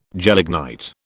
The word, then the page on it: gelignite: British English